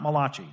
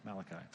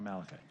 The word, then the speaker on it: Malachi: And so Malachi, not Malachi. He's not from Italy. Malachi. All right. Which, by the way, if you're in a Hebrew class or you've got a professor in front of you, he won't pronounce it Malachi. They always put that strong emphasis on the last syllable. Call him Malachi.